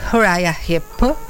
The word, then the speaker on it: Uriah Heep: Senti, tra l'altro invece un leitmotiv del tuo libro è la musica degli Uriah Heep, spero di averli pronunciati bene. Ecco, esatto.